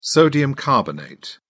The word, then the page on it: sodium carbonate: British English